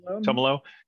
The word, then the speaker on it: Tumalo: I don't know if I'm pronouncing that exactly right. – Tumalo.